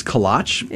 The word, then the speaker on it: kolache: I do like kolaches, though. Mm-hmm. I think it's kolache, right? – Isn't that the proper way to say it?